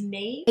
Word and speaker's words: Niamh: It's spelled N-E-V-E, but it is derived from that Irish N-I-A-M-H one.